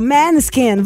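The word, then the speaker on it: Måneskin: Manskin. Ai, que chique, comprou. Pronúncia, o Maneskin.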